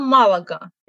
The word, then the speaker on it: Малага: Ну и «Малага», да, то же самое. Правильно, Малага. И я надеюсь, что в русских словарях будет Малага тоже.